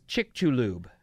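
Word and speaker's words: Chicxulub: There's an X in there somewhere, but the way you pronounce it is chick-chaloob. – Chick-chaloob.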